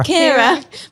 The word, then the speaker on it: Ciara: Ciara. Ciara.